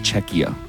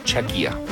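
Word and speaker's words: Czechia: It's pronounced Chechia. – Is it really? Mm-hmm. Don't tell me the wrong way. – I'm not. – I just heard that that was a country. – It's Chechia.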